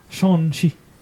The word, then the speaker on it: Shang-Chi: You have to do it a little more nasally and a higher pitch. Try it again. – Can't do that. Shang-Chi.